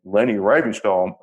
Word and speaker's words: Leni Riefenstahl: And Leni Riefenstahl, and I don't even know if I'm pronouncing her name right. – Yep.